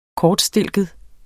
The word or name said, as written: kortstilket